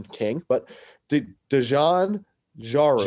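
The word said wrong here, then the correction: Dejon Jarreau: Dejon Giroux.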